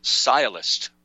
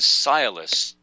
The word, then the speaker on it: sciolist: It's called sialist. The word is sialist or sialism, and it's spelled like science or scientist. A sialist, S-C-I-O-L-I-S-T, is a superficial pretender to knowledge.